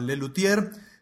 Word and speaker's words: Les Luthiers: se llama Le Lutier se escribe Les Luthiers, después de la T una H,